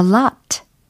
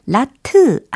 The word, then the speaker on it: lot: L-O-T, lot, lot, lot. 이렇게 발음 연습하시면 돼요. 라트 아닙니다. 우리가 끝부분에 트 이처럼 으 받침을 넣으시려고 하면 안 돼요. 영어에서는.